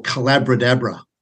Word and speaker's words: Collaboradabra: or Collaboradabra, which is rhymes with abracadabra.